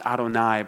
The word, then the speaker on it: Adonai: This means that these sailors are now using the personal name for Jonah's God, Yahweh, which out of respect would always be pronounced Adonai by Hebrew readers.